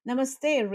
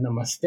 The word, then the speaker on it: Namaste: Yes, and thank you for correcting my pronunciation. Namaste. I got to get that right.